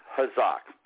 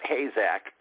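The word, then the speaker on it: chazak (חזק): But it's really pronounced chazak, not hazak, but it's pronounced chazak.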